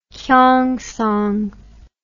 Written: Kyongsong

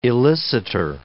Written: elicitor

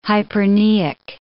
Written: hyperpneic

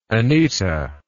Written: anita

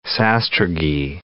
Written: sastrugi

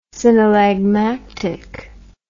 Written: synallagmatic

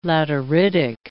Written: lateritic

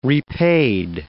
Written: repaid

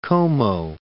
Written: Como

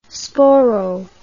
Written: sporo

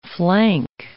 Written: flank

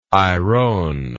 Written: irone

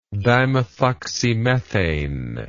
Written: dimethoxymethane

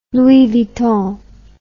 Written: Louis Vuitton

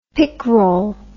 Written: picrol

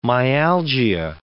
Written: myalgia

